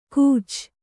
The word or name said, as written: ಕೂಚ